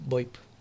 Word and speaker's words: VoIP: L'Ernesto ens deia, podem parlar de la telefonia VoIP. – Sí. – Lo he dicho bien, esto? – Sí. Sí. VoIP.